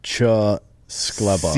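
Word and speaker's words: Czeskleba: Hoover Police Captain Keith, you fucker, Cislesba, how are we doing that, Cheskleba. – C-Z-E-S-K. – Cheskleba is how you say that.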